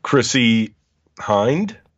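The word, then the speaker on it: Chrissy Hynde: Chrissy Hind.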